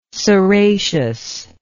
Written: ceraceous

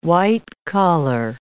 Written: white-collar

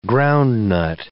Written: groundnut

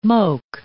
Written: moke

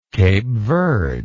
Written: Cape Verdean

